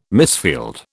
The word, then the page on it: misfield: British English